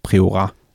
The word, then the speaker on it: Priorat: Men hvis du så kigger på et rigtig kendt område, Priorat, eller det staves faktisk Priorat, men jeg tror, katalanerne de udtaler det for Priorat.